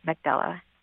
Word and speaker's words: Mekdela: And you can follow me on social media, on Twitter and TikTok, at Plus Size Book, and on Medium under my name, Magdala. – Which is spelled? – M-E-K-D-E-L-A.